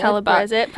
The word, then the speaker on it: Calabrese: Calabrese. Pronounce it properly like a true Italian.